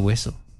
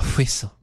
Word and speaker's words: whistle: Because this is a fairly sophisticated effort to write a narrative rather than blow a whistle. A whistle. – No, we're not starting that again. You don't need that much emphasis on the H.